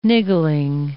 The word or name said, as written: niggling